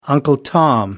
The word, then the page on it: Uncle Tom: American English